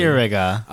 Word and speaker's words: Cicierga: Cicierga? I don't know how to say it. – Cicierga. – Oh, my Cicierga. God. – Cicierga.